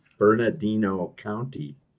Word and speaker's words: Bernardino: Bernadero, you'd have to pronounce it from San Bernardino County.